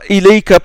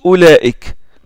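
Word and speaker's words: Olaik (أولئك): Ils ont mis ce waouh là pour te dire attention, la hamza qui est au début, ça se prononce O. Et ça, c'est euh, pour ça. Autre raison, c'est pour que tu ne la prononces pas Ilaika par exemple. Si ils enlèvent le waouh, la personne risque de la confondre avec Ilaika. Hein? Donc ils ont mis le waouh pour que tu lises Olaik.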